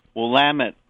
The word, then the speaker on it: Willamette: It's nice to hear Willamette pronounced the right way.